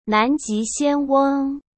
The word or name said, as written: Nanji Xianweng